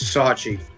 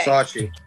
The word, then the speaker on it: Sarchie: It's Sarchie. – Sarchie. Okay. – Sarchie.